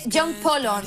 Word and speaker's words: Polon: No se dice como lo decimos todos. Yo he mirado y se dice John Polon. – Ah, Polon. – John Polon, Polon.